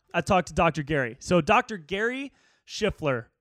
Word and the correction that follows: Shlifer: or Shlifer, Shiffler? Oh, shit. I'm sorry, Gary. I might have spelled your fucking name wrong, dude. Let me see it right now. Dr. Gary Shlifer, S-H-L-I-F-E-R, at Dr. Gary Evolve on Instagram.